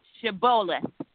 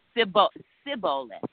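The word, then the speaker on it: Shibboleth: If he said no, they would say to him, then say, Shiboleth. And he would say, Siboleth, for he could not pronounce it right.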